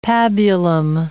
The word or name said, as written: pabulum